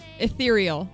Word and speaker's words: ethereal: I almost said urethral. – What? – Instead of urethral. Urethral? Is that how you say it? – Ethereal. – Ethereal, that's what I was trying to say, not urethral.